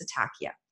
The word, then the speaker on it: ataxia: ataxia.